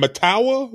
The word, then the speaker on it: Mettawa: Mettawa, M E T T A W A, Mettawa, Illinois.